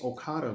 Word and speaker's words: Okada: They had Okada out there like El Duque Hernandez when he got signed to the Yankees talking about baseball's been very, very good to me. I'm like, it's Okada, brother.